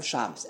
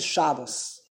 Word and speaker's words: Shabbos: You don't say Shabbos. Shabbos. Because it's Hebrew. And in Hebrew, it's milara. The emphasis on this last syllable.